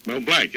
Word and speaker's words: Mel Blanc: Mel Blanc, yes.